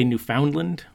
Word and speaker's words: Newfoundland: Well, the proper way is like Newfoundland. It rhymes with like understand. A lot of people say Newfoundland, and some people say Newfinland, but it's Newfoundland.